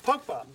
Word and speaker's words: Pogba: Pogba.